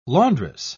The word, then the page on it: laundress: British English